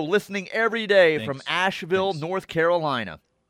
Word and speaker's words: Asheville: When you give a city in the South whose uh, city name ends in V I L L E, TJ, you got to pronounce it like V U L. Asheville. Asheville.